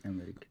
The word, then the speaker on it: Emmerich: ho detto giusto, no? Emmerich. Sì, sì, sì. sì. sì, sì Emmerich.